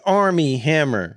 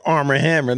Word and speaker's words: Armie Hammer: That's how I know you not know that, because you're not even saying his name right. – Army That's his name. – It's Armie Hammer. – That's what I'm saying. Armie Hammer. – You said Armor Hammer. – No, like Armie Hammer.